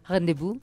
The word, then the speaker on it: rendezvous: ¿Lo dije bien? – Rendezvous. Rendezvous, gracias.